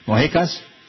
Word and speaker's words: Moquecas: M-O-Q-U-E-C-A-S. Moquecas? – Moquecas.